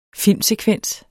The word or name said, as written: FILMSEKVENS